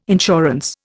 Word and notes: insurance: Indian

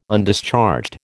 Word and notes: Undischarged: American English

